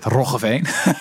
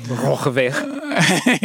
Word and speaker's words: Roggeveen: You probably can't pronounce that, Roggeveen. – Roggeveen. – Yeah, there you go.